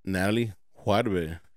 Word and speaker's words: Natalie Juarbe: Without further ado, ladies and gentlemen, Natalie Juarbe.